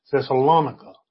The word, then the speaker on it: Thessalonica: And in our text today, Paul has traveled across into the city of Thessalonica, is the way we usually hear it spoken. Another way is Thessalonica. That's another way of pronouncing it. I usually use Thessalonica.